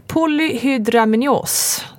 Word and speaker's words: polyhydramnios: Och anledningen till igångsättningen är polyhydramnios.